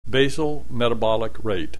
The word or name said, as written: basal metabolic rate